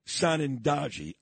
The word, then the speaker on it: Sanandaji: Her name is Natalie Sanandaji, I think.